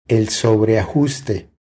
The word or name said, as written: El sobreajuste